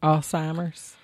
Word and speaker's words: Alzheimer's: Mother has been battling Alzheimer's. Am I even pronouncing that right? – Alzheimer's. – Yes, girl. It's like, how are you going to pronounce the Z? – Yes.